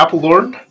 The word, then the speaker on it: Apeldoorn: Coming to us from Apeldoorn. Am I saying it right? – Yeah, Apeldoorn. Brand new office.